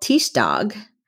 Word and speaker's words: Tirsdag: So Tuesday is called Tirsdag, but it's spelled like T-I-R-S day.